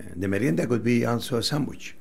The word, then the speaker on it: merienda: How do I say that? Merienda? – Merienda.